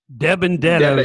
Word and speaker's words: DiBenedetto: DiBenedetto.